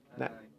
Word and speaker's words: Nanai: Nanoi, is that how you pronounce it? Nanani? Anybody know where that name comes from? What? – Nanai. – Nanai, okay. Is it short for anything?